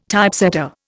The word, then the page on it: Typesetter: Indian